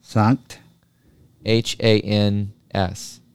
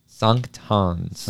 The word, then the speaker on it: Sankt Hans: The first word is S-A-N-K-T. – Sankt. – H-A-N-S. – Hans. – Sankt Hans. – Sankt Hans. – Hopefully I pronounced that correct enough.